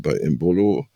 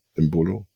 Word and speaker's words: Embolo: wie zum Beispiel bei Embolo, Betonung auf der letzten Silbe, auf der zweiten Silbe, Embolo, oder Embolo,